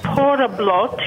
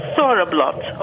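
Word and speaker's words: Þorrablót: Okay, first thing, I thought maybe it would be nice to understand that it looks like it's written with a P, but actually that letter is pronounced like our English TH. Okay, so you don't call it Thoriblot, you call it Thoriblot, okay?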